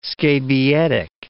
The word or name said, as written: scabietic